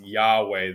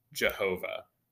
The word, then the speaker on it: Yahweh: Most likely, again, we don't 100% understand how Hebrew has sounded at all times, but it is more likely that his name is supposed to be pronounced Yahweh than Jehovah, just based on the best that we can guess.